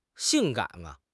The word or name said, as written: 性感